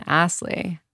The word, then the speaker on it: Aslay: I'm so excited to be here with self-love advocate, Jonathon Aslay.